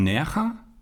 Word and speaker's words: Nerja: Und dann gibt es die Höhlen von Nerja. Schreibt sich Nerja. – Aber gut ausgesprochen.